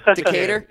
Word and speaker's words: Decatur: I noticed you were born in, I don't know if I'm going to murder the pronunciation here, Decatur, Georgia? – Decatur. – Yeah. So there you go.